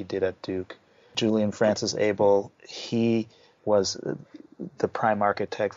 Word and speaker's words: Abele: His name is actually Abel. And I used to say it as that when I first saw it as well, because there are African names with that spelling and that pronunciation, but it's Abel.